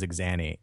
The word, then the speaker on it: Axani: Her heritage is Romanian, and normally it's Azani, Azani, which is apparently a very typical name in Romania. – Yeah. – But there's like my only my family has Axani, and none of us know how to say it.